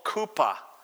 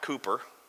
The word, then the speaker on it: chuppah: Now, the second stage of the marital process is called kupa, not Cooper. Spencer's like, hey, they named it after me. That's awkward. C-H-U-P-P-A-H. It's pronounced kupa.